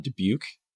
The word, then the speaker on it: Dubuque: You call it Dubuque. Am I saying that right? – Yes.